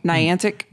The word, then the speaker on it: Niantic: Niantic.